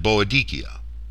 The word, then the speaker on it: Boadicea: These names will be pronounced using the European continental rules for vowels and a hard C throughout, the Ikeni and Boadicea.